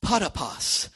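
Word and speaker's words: potapos (ποταπός): The word manner in the Authorized Version is the word potapos. Potapos.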